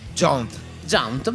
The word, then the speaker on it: Jaunt: Jaunt, non so bene la pronuncia. – Jaunt, bene.